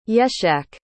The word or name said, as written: yeshak